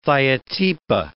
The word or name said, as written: thiotepa